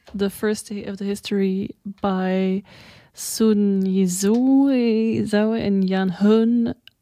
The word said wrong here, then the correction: Yan Jun: Really sorry, Yan Jun.